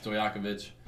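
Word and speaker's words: Stojaković: Peja Stojakovic, or however you say his name. – Stojakovic. – Stojakovic.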